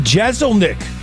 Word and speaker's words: Jeselnik: On the Celebrity Hotline right now with Anthony Jeselnik, stand-up comedian coming to Milwaukee this weekend.